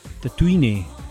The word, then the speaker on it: Tatooine: es como tatuaje, tatu, de tatuaje, ine, Tatooine.